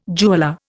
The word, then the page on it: jeweller: Indian